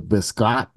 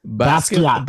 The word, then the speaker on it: Basquiat: You look crazy with your hair like that, like a fucking broke ass Jean-Luc Biscott. Biscott? Is that how I say the name right there? Biscott? – Biscott? Biscotti? – Biscott. Basquiat.